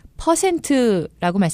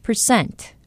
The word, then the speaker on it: percent: 단지 발음하실 때 퍼센트라고 말씀하시지 마시고 percent 이렇게 발음한다는 거 기억하시기 바랍니다.